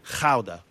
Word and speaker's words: Gouda: Gouda. That's how it's pronounced. But anyway, you were saying... – What? – Sorry. That's how it's pronounced. It's pronounced Gouda.